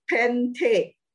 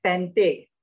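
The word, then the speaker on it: Pente (πέντε): And a five in Greek is, how to pronounce this? Better pronounce this properly. Follow the phonetic. Pente, te, te, te, te. Any te around Chinese surname. Pente, pente. Okay, so remember, pente. It's number five.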